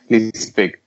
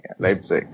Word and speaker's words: Leipzig: So, I mean, and a club like Red Bull Leeds, I don't know how to pronounce that, Leeds Pick. – Leipzig. You know, Leipzig, yeah. Thank you.